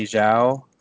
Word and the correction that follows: Jaso: or how you pronounce that. – Manny Jaso. – Jaso.